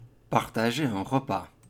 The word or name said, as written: Partager un repas